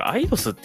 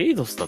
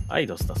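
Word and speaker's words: Eidos: えー、に譲渡さえー、ここに譲渡されたのはクリスタルダイナミクス、えー、そしてアイドス、えー、これアイドスって読むんだね。俺エイドスだと思ってた。アイドスだそうです。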